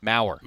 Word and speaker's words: Maurer: Because their Maurer is spelled weirdly, M-A-U-R-E-R, as opposed to like Joe Maurer, M-A-U-E-R. So there's an extra R in there, so it kind of blends it together.